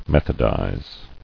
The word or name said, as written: Methodize